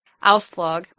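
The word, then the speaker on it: Aslaug: So now we are 4 years later, and the scene opens up with Auslaug, which that is the pronunciation, because I watched an interview with Alyssa Sutherland, mm-hmm. and that's how it is pronounced.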